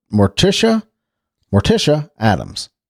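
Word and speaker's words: Morticia Addams: Number 2, Morticia Adams.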